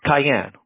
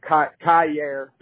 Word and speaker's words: Kyair: Oh, Cayenne, like the car? – No. Kyair. So it's spelled K-Y-A-I-R.